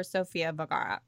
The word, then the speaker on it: Sofia Vergara: I really would watch this show only for Sofia Vergara.